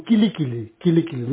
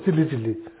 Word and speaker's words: Kili Kili: The mantra you should supposed to recite the mantra like Om Kili Kili, not Tili Tili. Kili Kili. That's how you pronounce correctly, Kili Kili.